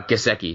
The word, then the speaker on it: Gesicki: Is that, or Gesicki? Is that how you say his name? – Gesicki, yeah.